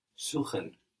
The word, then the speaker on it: suchen: Suchen. – Suchen. So an S vowel syllable gives us a Z sound.